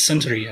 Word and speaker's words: Suntory: I think it's Suntory. I think you got it right the first time.